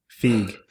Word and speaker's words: Feig: Feig. – Who cares? Feig.